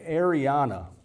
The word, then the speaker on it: eirene: And the, the word is translated eirene.